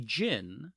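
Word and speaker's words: djinn: That's how you pronounce it, right? D G I N N. – Yeah, jinn. Right? Yeah, jinn. You're right.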